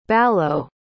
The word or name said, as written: ballo